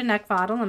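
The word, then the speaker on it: Neckvatal: You can find me over on YouTube and LinkedIn at Brenda Neckvottle, and my last name is spelled N-E-C-K, like the thing you want to choke. V as in Victor, A-T-A-L.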